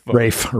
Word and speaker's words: Ralph: I could never get the part past the part of him pronouncing his name Ralph. – Rafe, or whatever Rafe.